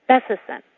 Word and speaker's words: Bessison: My last name is often mispronounced because people put the accent on the wrong syllable. The accent should be on the first syllable. Bessison. It's a Norwegian name, meaning son of Bessie.